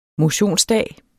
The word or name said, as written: motionsdag